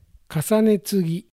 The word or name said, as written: Kasanetsugi